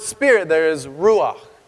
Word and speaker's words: ruach: And it's this fun Hebrew word that I don't know if I have a robust enough throat to say, but it's this word ruach. And I think you get really guttural when you say it correctly.